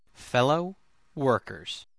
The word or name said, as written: fellow workers